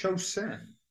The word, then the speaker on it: Chosin: Is it Chosin or Chosin? I don't know how to pronounce it. – It's Chosin.